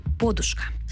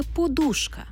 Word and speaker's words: подушка: А в сучасних словниках тільки подушка в однині, у множині – подушки.